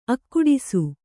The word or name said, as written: ಅಗಡಿಸು